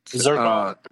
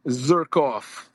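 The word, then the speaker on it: Tverskov: And we also have from San Diego FC representing Coastal Selection, Jeppe, how do you say that? – Zirkov. – Thank you. Zirkov.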